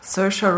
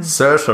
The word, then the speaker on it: Saoirse: Saoirse Ronan. – Saoirse Ronan, genau.